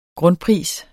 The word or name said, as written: grundpris